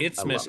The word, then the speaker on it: isthmus: Isthmus is a word that I know because of the Madison Mallards. – And it's the only word that rhymes with Christmas, which I also learned, which is very appropriate for today.